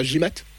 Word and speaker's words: Gimatt: J'ai bien prononcé votre nom de famille, c'est bien Gimat? – Oui, c'est bien ça.